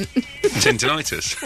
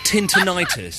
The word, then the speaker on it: tinnitus: My ability to understand words is impaired because you pronounce tinnitus. Tintinitis. You cannot fob that off on your dyslexia. – I can, because I read it as tintinitis.